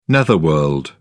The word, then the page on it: netherworld: British English